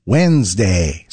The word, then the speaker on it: Wednesday: It's midweek Wednesday, which of course we pronounce Wednesday. So why are we spelling it with a random D that nobody invited to the conversation? English is full of words like that. Take Colonel, for example. It's spelled C-O-L-O-N-E-L, but pronounced Colonel.